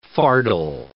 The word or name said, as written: fardel